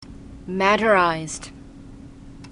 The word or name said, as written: maderized